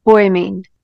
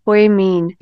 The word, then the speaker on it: Poimen: Poimen. P O I M E with a little dash on the line. That means it's a long E, hardy, and N. Poimen.